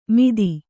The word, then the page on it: ಮಿದಿ: Kannada